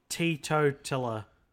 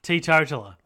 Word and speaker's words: teetotaler: Tea, like tea, like you drink tea, teetotaler.